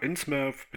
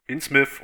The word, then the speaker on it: Innsmouth: Innsmouth oder Innsmouth ist übrigens so ein Tomato-Tomato-Ding. Also keine Ahnung, wie die Stadt nun ganz genau ausgesprochen wird. Ich orientiere mich da mal an Plymouth und unterm Strich ist die Stadt ja effektiv.